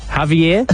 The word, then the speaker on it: Javier: La Liga president Javier, I'm going to go with, because it's Spanish, Kyle. So the J-A-V is pronounced Hav, isn't it?